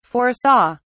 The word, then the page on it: foresaw: British English